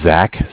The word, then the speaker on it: Zach: My name is Zach, spelled Z-A-C-H. I'm calling with Bank of Cardiff. Cardiff is spelled C-A-R-D-I-F-F.